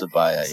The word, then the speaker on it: Sibaya: that how you pronounce yeah. it? Sibaya. Sibaya, yeah.